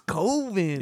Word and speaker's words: Coven: It doesn't rhyme with oven. It's Coven.